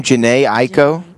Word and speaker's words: Jhené Aiko: Jhené Aiko? Is that how you say it? – Mm-hmm.